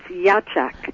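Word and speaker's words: Iachak: Tell me, did I pronounce that right, Ichak? Ichak? – It's Yachak. – Yachak, okay.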